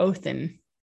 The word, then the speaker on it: Odin: So Odin, or Othin, if you're pronouncing it like you're supposed to.